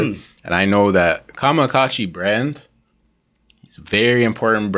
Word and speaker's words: KAMAKACCI: So it's KAMAKACCI, because a lot of times people say Kamazi, KAMAKACCI, this, that, and the third. It's pronounced KAMAKACCI.